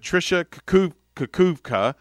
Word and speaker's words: Trisha Kukuvka: So we have pinch hitting for them is a young lady by the name of Trisha Kukuvka.